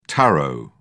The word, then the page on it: tarot: British English